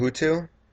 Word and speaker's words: Hutu: Now, how can you tell the difference between a Hutu? Is that how you pronounce Hutu? – Yeah.